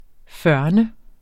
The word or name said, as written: førn